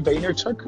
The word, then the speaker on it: Vaynerchuk: Este, es Gary Vaynerchuk, o sea, la, pronunciación correcta, Gary Vaynerchuk, pero no sé por qué es Vayner, bueno, porque se me quedó Vayner, o sea, Vayner Media, Vaynerchuk, o sea, si alguien me quiere criticar por eso, adelante, háganlo.